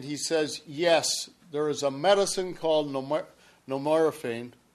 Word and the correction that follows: nevirapine: How do I pronounce this? – Nevirapine.